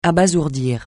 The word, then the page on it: abasourdir: francais